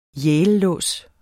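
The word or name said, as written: YALELÅS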